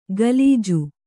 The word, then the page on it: ಗಲೀಜು: galīju